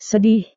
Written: sedih

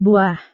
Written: buah

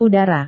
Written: udara

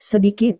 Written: sedikit

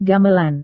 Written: gamelan

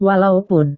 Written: walaupun

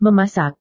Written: memasak

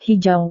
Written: hijau

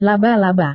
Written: laba-laba

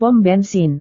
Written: pom bensin

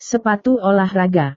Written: sepatu olahraga

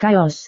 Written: kaos

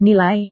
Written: nilai

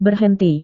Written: berhenti

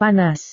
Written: panas

